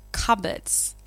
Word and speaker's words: cupboard: Okay, it's like two words put together, cup and board, but we don't say cup and board. – And the P is silent. – Exactly, the P is silent, so it's cupboard.